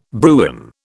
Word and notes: Bruin: British English

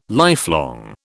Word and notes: lifelong: British English

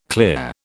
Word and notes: clear: British English

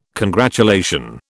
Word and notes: congratulation: British English

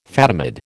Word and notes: Fatimid: American English

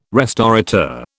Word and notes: restaurateur: British English

